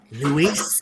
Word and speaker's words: Luis: Louise. – Joker, say it correctly. – Luis Guzman.